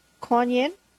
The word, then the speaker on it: Kuan Yin: You know, there's a story about Kuan Yin. Did I say that right? – Mm-hmm.